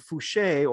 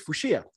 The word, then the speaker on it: Fucher: So with Eddie Fushia making it 6-10 after the extras, by the way, Fushia, F-U-C-H-E-R, so I would have assumed Fouché or Fusher, but apparently it's Fushia.